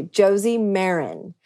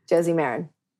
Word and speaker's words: Moran: Josie Moran. And it rhymes with Karen, which has gotten kind of a funny stigma in the last few years. But Josie Moran.